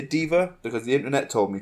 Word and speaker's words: deva: All right, so, guys, I have planetars, and I have divas. And according to the internet, in multiple places, it is pronounced diva and not deva.